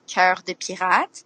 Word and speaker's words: Cœur de Pirate: Well, my name, how it's pronounced, is Cœur de Pirate.